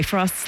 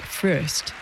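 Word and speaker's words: Fröst: By the way, Frost's last name is spelled with two dots over the O in umlaut, so in his native Swedish, it's pronounced more like Frost. But in English, everyone seems to just agree to pronounce it Frost.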